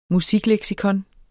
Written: musikleksikon

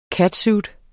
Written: catsuit

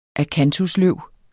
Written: akantusløv

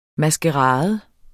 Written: MASKERADEN

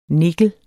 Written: nikkel

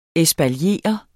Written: ESPALIERE

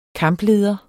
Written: kampleder

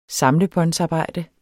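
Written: samlebåndsarbejde

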